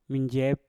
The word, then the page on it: minjep: Mpur